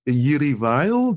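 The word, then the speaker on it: Jiří Weil: Mendelssohn Is on the Roof by Jiří Vajl, do I pronounce that name correctly? – That's fine. Yeah.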